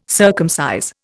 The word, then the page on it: circumcise: Indian